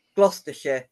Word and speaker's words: Gloucestershire: Gloucestershire, yeah.